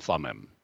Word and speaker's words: Thummim: In the Old Testament, the high priest had a couple of objects that were known as the Urim and Thummim, or as Americans say, the Urim and Thummim.